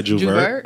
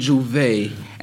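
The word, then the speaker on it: J'ouvert: Say Juvert. – I've been saying Juve, and then my girl made me Google it, and it says it's spelled Juvert. But I mean, it's pronounced Juve.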